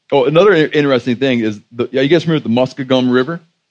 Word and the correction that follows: Muskingum: How do you guys say it? Muskegum. You know what it means? Okay, one dude say it. Muskingum.